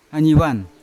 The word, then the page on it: anivan: Yawa